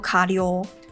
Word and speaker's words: 過料: 过料它就念作卡溜。